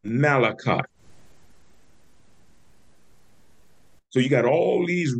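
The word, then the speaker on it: Melachot: And it's pronounced Malachot. M-E-L-A-C-H-O-T. M-E-L-A-C-H-O-T. Malachot.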